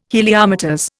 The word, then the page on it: Heliometers: Indian